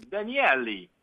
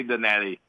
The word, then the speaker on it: Danielli: Well, if you say very Italian, it's Danielli, you know, but you can say Danielli.